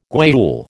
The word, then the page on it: guayule: American English